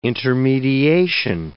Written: intermediation

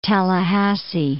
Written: Tallahassee

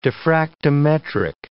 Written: diffractometric

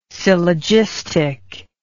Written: syllogistic

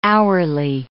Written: hourly

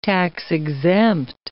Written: tax-exempt

